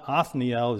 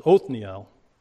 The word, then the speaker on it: Othniel: It's not a kametz, which is the A sound, but it's the kametz hatuf, which is an O sound. And we know that because it is a pretonic closed syllable. So I hope Pastor Rosen is watching this. I got that. It's not Othniel, it's Othniel.